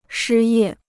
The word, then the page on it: 失业: shī yè